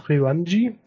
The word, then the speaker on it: Ryoanji: Im Ryoanji. – Ah. – Ryoanji.